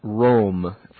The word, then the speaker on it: ROLM: That's pronounced Rome, for those of you that are curious. R-O-L-M.